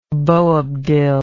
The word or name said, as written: boabdil